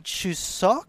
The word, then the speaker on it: Chuseok: And Chuseok, I believe it's pronounced, September 12th again.